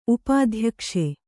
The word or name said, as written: ಉಪಾಧ್ಯಕ್ಷೆ